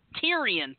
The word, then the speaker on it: Tyrion: Tyrion, thank you. – Tyrion Lannister. – Okay, but it is spelled T-Y-R-I-O-N, correct? – Correct. Tyrion Lannister.